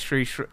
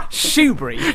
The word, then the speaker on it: Shrewsbury: It's Shrewsbury. Stop. Shrewsbury.